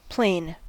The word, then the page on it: plane: American English